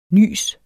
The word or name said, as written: Nyset